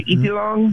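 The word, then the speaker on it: Itliong: And the other one is Larry Iti, if I'm saying it wrong, Filipino folks correct me, but it's Larry Itilong.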